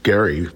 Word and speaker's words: Gerry: Albert Gerry, is actually how it's pronounced, was standing right there.